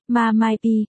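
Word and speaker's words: MA, mai, pi (妈卖批): Mụ bán phê, ma mai pi, phát âm là MA, mai, pi, có thể viết thành từ viết tắt MMP.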